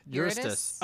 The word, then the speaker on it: Eurystheus: So one thing that I have here is the story of Eurydice? – Huh? – Eurystheus. Oh, Eurystheus? I said it right the first time. I don't know why I'm struggling. Yeah. Eurystheus.